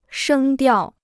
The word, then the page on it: 声调: shēng diào